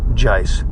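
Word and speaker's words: Guice: Gyce, the running back, Gyce, G-U-I-C-E. I believe it's pronounced Gyce.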